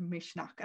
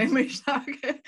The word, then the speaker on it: Misneach: So one of those words that I've learned from you is the word Misneach, and I don't even know if I'm saying it right. – You are. Yeah. No, I go Misneach, and I just found you know the little bits.